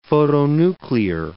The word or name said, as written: photonuclear